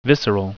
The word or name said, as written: visceral